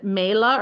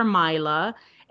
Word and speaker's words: Mayla: You can also pronounce it Mayla or Myla.